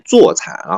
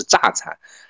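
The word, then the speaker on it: 柞蚕: 然后呢，他说这个这正常应该是念坐蚕，然后呢，你可以念多音字是榨蚕。但是你念的是榨蚕，他这搞了这么一条评论，他说你作为一个养蚕的，你首先应该把这个普通话学好吧。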